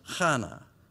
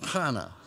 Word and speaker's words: Hannah: Anna's name is from the Hebrew, and in Hebrew it's pronounced Hannah. Why don't you say that with me tonight? That'll just get us all involved. Hannah.